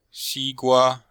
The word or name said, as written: xi1gua1